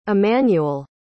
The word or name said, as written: immanuel